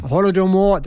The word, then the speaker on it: Holodomor: Holodomor, that's the correct pronunciation.